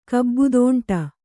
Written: ಕಬ್ಬುದೋಂಟ

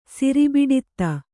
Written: ಸಿರಿಬಿಡಿತ್ತ

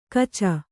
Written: ಕಚ

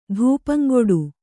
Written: ಧೂಪಂಗುಡು

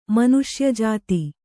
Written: ಮನುಷ್ಯಜಾತಿ

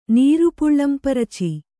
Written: ನೀರುಪುಳ್ಳಂಪರಚಿ

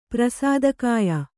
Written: ಪ್ರಸಾದಕಾಯ

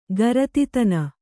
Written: ಗರತಿತನ

